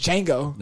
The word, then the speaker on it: Django: The D is silent, bro. It's like an emerged D and J, so it's, you know, Django.